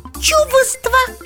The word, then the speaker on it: чувство: Непроизносимая согласная В. – Чувство.